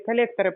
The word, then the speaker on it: коллекторы: Коллекторы, правильно говорить.